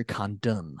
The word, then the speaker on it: Kerry Condon: Kerry Condor is incredible. Like the cast is just so fucking good. Really. Kerry Condon. I got that name wrong too.